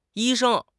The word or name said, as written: yi1 sheng1